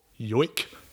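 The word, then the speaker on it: joik: And it's actually like a traditional yoik is the pronunciation.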